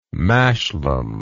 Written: mashlam

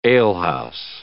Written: alehouse

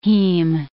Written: heme

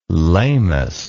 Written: lamus

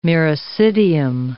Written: miracidium

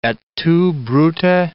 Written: et tu Brute

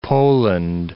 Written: Poland

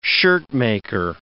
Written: shirtmaker

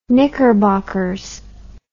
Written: knickerbockers

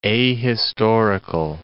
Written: ahistorical

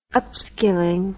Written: upskilling

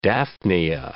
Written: daphnia

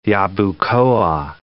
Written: Yabucoa

